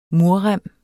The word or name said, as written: murrem